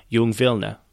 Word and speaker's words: Jung Vilna: It was in this vibrant city that a group of young writers got together, calling themselves Young Vilna, or in the Yiddish pronunciation, Jung Vilna.